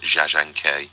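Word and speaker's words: Jia Zhangke: And if I may, the director that you mentioned at the end, Jia Shanke, is Zha Zhangke. That's how I was told to pronounce it.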